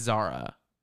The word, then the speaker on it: Zara: she introduces herself as Sarah, but it's spelled Z-A-R-A. And I'm like, it's Zara.